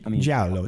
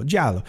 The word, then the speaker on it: Giallo: these Giallo, how did you say it? – Giallo. – Giallo. Genre of films is like, it's like, it's just weird.